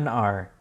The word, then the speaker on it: XR: 10R, obviously. – 10R, okay. – You pronounce it 10.